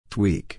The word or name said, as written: tweak